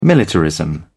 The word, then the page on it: militarism: British English